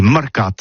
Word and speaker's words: marcats: avec un A, marcat. En gascogne toulousaine, bah, du côté, par exemple, de l'île des Baches, c'est l'île Jourdain, pour parler du marché, vous prononcez les marcats.